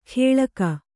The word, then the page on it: ಖೇಳಕ: khēḷaka